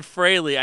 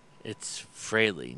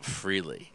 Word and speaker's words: Frehley: Yeah, he said Frehley. I noticed that right off the bat. That's funny. It's almost like Ace went, it's Frehley, not Frehley.